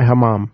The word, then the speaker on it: hammam: They have something that what is known as the hammam, or did I say that right? – Hammam. You did.